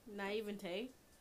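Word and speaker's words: naïveté: Naivete. It's like Nevea. The name Nevea. Heaven backwards.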